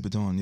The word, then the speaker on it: Dupiton: Dupiton, yes. – Yeah, it sounds like Greg Popone.